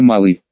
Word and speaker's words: Малый: Меня зовут Мартимер, а Малый – это моя фамилия, с ударением на первый слог. Мартимер Малый.